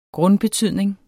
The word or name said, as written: grundbetydning